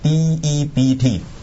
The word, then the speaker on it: debt: 其實一個字咋嘛、啊、英文就四個字母，就係、是、 DEBT。但是很多人都讀錯的因為個 B 字不發音的，就是讀 debt 就不是讀 debt，你讀 debt 就錯了的，是 debt、知不知道 debt，但是、那個音其實跟 DEAD 都差不多，那個就 dead 就是死得。